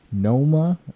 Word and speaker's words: Nooma: Noma. I'm gonna make sure I say this right. Spelling is N-O-O-M-A.